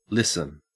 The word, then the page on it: listen: Australian English